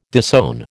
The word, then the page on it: disown: American English